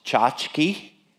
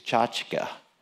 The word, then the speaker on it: tchotchke: This is the word tchotchka. Now, you may hear it more often pronounced tchotchke. Officially, it's tchotchka. It's a Yiddish word.